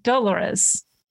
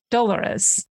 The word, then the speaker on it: dolorous: And it's dolorous, dolorous, D-O-L-O-R-O-U-S, kind of like Dolores, which is how I want to pronounce it. But actually, the emphasis is on the duh, dolorous.